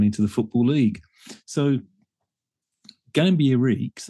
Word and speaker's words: Gambier Reeks: Gambier Reeks, who in 1904 lived over the road from the stadium that was emerging on the Fulham Road at Stamford Bridge.